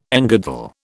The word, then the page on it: engirdle: British English